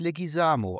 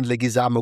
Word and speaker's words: Leguizamo: Okay, wie falsch habe ich es ausgesprochen? – Ich glaube, er heißt Leguizamo. Also von daher war es nicht so schlecht.